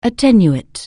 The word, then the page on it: attenuate: anglais